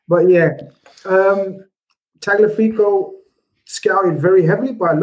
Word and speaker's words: Tagliafico: Tagliafico.